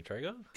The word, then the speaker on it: Trejo: Danny Trejo? – Trejo, yeah.